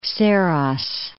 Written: Saros Gulf

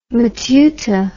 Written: matuta

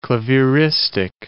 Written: clavieristic

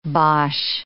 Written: bosh

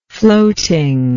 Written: floating

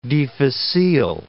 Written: difficile

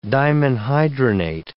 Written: dimenhydrinate

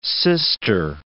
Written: sister

